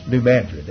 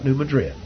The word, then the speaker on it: New Madrid: Now, you may think I'm mispronouncing that, but if you're from Missouri, you would know that that's New Madrid. That's not New Madrid.